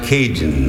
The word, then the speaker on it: Cajun: In the South Louisiana, a carillon or carillon being a true Southern, he just shortened it to cajon, cajon, cajon. In English, that's pronounced Cajun.